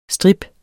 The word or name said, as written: strip